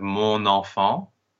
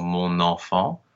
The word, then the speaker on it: mon enfant: We have mon enfant, because the word mon technically has a silent N, but when it's followed by a vowel, the N becomes pronounced, so mon enfant.